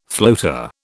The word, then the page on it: floater: British English